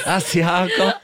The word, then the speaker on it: Asiago: Asiago.